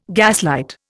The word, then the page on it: gaslight: Indian